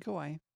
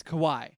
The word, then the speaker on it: Kawhi: Stop saying Kawhi. – Kawhi. Can't say his name. – It's Kawhi. – Kawhi. – Just say the word why and say ka in front of it.